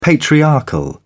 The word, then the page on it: patriarchal: British English